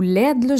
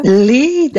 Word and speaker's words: LEED: Est-ce que c'est un peu comme la certification LEED ou LED? Je ne sais pas comment on la prononce. Là. – LEED, d'abord.